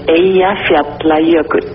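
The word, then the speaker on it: Eyjafjallajökull: Эйя-Фьядла-Йокюдль.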